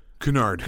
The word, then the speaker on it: Cunard: Is that how it's pronounced? – Cunard. Yeah.